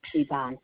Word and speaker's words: Yvonne: Yvonne Thomas. Y-V-O-N-N-E. Last name Thomas, T-H-O-M-A-S.